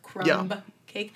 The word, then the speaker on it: Krumkake: But to be fair, it was spelled K-R-U-M-K-A-K-E. So it wasn't like C-R-U-M-B, like crumb cake. – Crumb cake? – Yeah, but it's pronounced the same way.